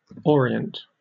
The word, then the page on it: orient: British English